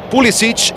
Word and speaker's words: Pulisic: Pulisic, en su arranque en la pronunciación, él mismo le gusta Pulisic, pero usted le puede decir como quiera.